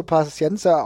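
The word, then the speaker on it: Paciência: Okay, dafür, dass er Portugiese ist, gehe ich trotzdem davon aus, dass er dann auch so Paciencia ausgesprochen wird. Also dann haben wir es doch. Paciencia.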